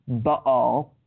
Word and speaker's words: Baal: Some people pronounce Baal as Baal. In Hebrew, it should be pronounced Baal. B-A-A-L.